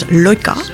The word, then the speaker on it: Laika: Was it Laika? – Laika, or in the right pronunciation is Loika.